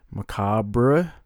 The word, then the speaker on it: macabre: I don't believe you pronounce the RE. Macabre.